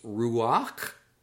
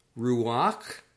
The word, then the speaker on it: ruach: Word 36 means breath or wind, and it is pronounced ruach, ruach.